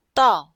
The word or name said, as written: dao4